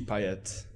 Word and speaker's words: Payet: I support Marseille, and my favorite player is Dimitri Payet.